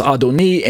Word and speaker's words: Adoni: However, on the left side of the screen, we have the comparison Adoni with the little dot, the vowel point marking chirik, which gives us the long E sound, and it's under the same letter N, and this tells us that the rendering should be pronounced Adoni,